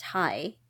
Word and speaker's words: Hye: When we immigrated to the United States, they spelled my name H-Y-E, which would be pronounced Hi in English.